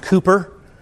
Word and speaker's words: Cowper: It's spelled Cowper, but it's pronounced Cooper.